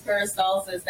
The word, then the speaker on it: peristalsis: Technically, it's peristalsis that keeps everything moving in your digestive system.